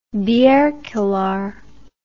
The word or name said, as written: bierkeller